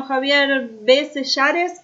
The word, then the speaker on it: Javier B. Sellares: Bueno, Javier B. Sellares, ¿pronuncio bien tu apellido? – B. Sellares, sí, pero algunos me dicen B, no te preocupes, bueno, es B. Sellares. – Javier B. Sellares, muchas gracias por estos minutos con CDM Noticias.